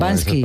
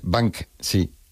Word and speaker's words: Banksy: Pero bueno, como Bansky. – Esas palabras. Es Banksy.